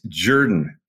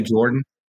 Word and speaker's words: Jordan: You got to say that right. It's Jordan. You know that, right? They don't say Jordan.